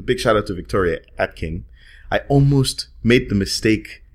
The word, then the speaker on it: Atkin: There's some video assets that will be coming out where I mistakenly pronounced her surname as Akin. – I keep thinking that it's Akin too, and it's not.